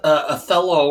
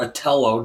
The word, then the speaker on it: Othello: Except uh, Othello or Othello, depending on how you decide to pronounce it, was uh, an African.